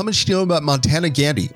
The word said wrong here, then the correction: Mohandas Gandhi: Hang on. Okay, Mohandas Gandhi, what do you know?